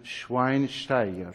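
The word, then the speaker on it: Schweinsteiger: میگن شواین در صورت که نام ایشون رو باید به شکلی تلفظ بکنید، شین بی اعراب باشه شواین شواین شواین باستیان شواینشتایگر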